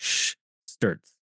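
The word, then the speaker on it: Szturc: It's S-Z-T-U-R-C, but it's like Scherzer. That's basically how you say it.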